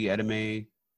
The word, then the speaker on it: edamame: Yeah, like the $100, you can get a Nobu Dallas hot bento, which is spicy edamame, California. – wait. Spicy what? – Oh, how do you say that? E-D-A-M-A-M-E? How do you say it? – Try it again. – Edamame. – Edamame, same thing.